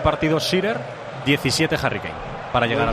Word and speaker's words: Shearer: igualando a Shearer, con 7 goles ambos. – ¿Ha dicho Shearer? – Es que no sé pronunciarlo muy bien, la verdad. – Alan Shearer.